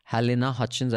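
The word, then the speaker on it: Halyna Hutchins: Alec Baldwin was pointing a prop gun and he shot his cinematographer, Halina Hutchins. I think that's how you pronounce her name.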